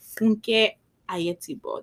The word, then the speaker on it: Funke Ayetigbo: Her name is Funke Ayetigbo.